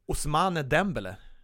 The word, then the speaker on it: Ousmane Dembélé: Och en av dem som har gjort det på ett eget sätt är Ousmane Dembélé. Jag kan säga att det var perfekt uttal.